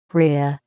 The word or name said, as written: rhea